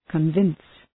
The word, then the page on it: convince: kən’vıns